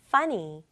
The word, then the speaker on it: funny: 그리고 이 n 두번 왔다고 n 발음 두번할거 아니에요. funny 아니고 funny. okay?